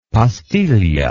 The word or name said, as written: pastiglia